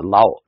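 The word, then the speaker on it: Lau: 伦敦大学有一个国王学院叫做就是里面有一个叫刘氏中国研究所所长啊，这个听起来就像是一个用广东话发音的啊，姓刘的一个人啊，就是 The Law China Institute 啊，就是一个姓刘的这个在英国的智库，不知道是这个跟这个香港或者是跟中国大陆有什么关系哈。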